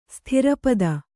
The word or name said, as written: ಸ್ಥಿರಪದ